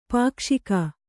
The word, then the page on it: ಪಾಕ್ಷಿಕ: pākṣika